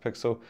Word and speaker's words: PXL: But it is spelled PXL, pronounced Pixel.